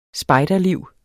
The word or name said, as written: spejderliv